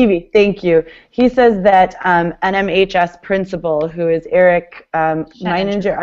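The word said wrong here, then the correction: Sheninger: I always say his name wrong too, but... Eric Scheninger, thank you, is an expert when it comes to school social media and policies, and that's totally true.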